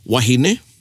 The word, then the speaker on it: wahine: And then of course we have wahine, which means woman, W-O-M-A-N. And then you have wāhine, which means? – Woman. – Woman, W-O-M-E-N.